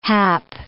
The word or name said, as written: hap